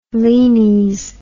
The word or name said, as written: lenes